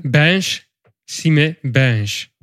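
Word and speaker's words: Binche-Chimay-Binche: Hoe spreek je het eigenlijk wel uit, voor de volgende keer? – Bench, Sime, Bench. – Dankjewel.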